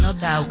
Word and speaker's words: No Doubt: No doubt. – No doubt.